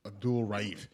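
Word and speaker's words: Abdul-Rauf: Abdul Roof? Or am I mixing up? – No, you did butcher his name, but. – All right. – Mahmoud Abdul Raif.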